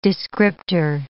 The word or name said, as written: descriptor